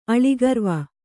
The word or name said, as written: ಅಳಿಗರ್ವ